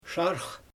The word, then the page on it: ʃaarx: Xyzyl